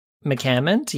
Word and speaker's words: McCammond: So, this next one, this is Alexi I don't know how to pronounce her last name. Alexi McCammond. McCammond, yeah.